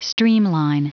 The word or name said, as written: streamline